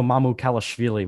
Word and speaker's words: Mamukelashvili: Unfortunately, the highest valued name from Seton Hall, Sandro Mamukelashvili, will not be in the tournament. – There you go. You said it correctly.